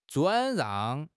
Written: 转让

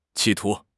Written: 企图

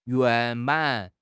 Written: 圆满

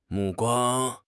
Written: 目光

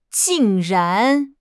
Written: jing4 ran2

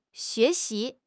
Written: xue2 xi2

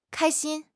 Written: kai1 xin1

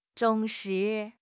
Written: 忠实

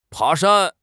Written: pa2 shan1

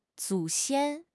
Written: 祖先

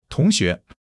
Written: tong2 xue2

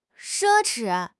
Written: 奢侈

